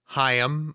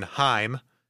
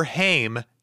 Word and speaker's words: Haim: and they usually said Hyam with two syllables, or sometimes even Heim, as I am usually going to say throughout this thing, but never Haim, as in Corey.